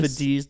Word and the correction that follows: Vitesse: It's God, your pronunciation on shit is so whack. – Vitesse. – It's Vitesse. Yeah.